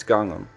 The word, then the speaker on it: Gangnam: Anyway, his Gangnam, whatever it's pronounced, I don't care. His big Gangnam Style hit, it's Gangnam. That's right, that's how he says it in a song.